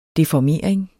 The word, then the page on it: deformering: defɒˈmeˀɐ̯eŋ